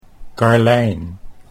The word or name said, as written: Karlijn